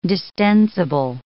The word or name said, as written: distensible